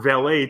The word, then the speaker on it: valet: Several characters pronounce the word valet to rhyme with ballet. This mispronunciation did not gain popularity in the United States until after 1950. Before then, it was pronounced a rhyme with mallet.